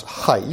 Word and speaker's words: Hai: The Monteverdi, well, H-A-I, which I guess I produce, you pronounce Hai.